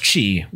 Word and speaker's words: qi: In Chinese or in Mandarin, qi is pronounced qi with a falling tone.